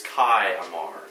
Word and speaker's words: Kayamar: It may be spelled with a small I, you'd think that'd be a Latin vowel, it's not. It's Kayamar.